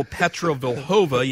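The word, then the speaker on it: Petra Vlhová: Well, her biggest rival, Petra Vlhova, yes, I said that correctly, injured her knee and was ruled out for the year earlier this week.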